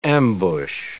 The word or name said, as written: ambush